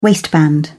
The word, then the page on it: waistband: British English